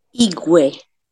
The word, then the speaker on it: Igue: Now, I'm not sure if I'm pronouncing Igue properly. Could you please pronounce that word? – Igue. – Oh, Igue. Oh, okay, the Igue.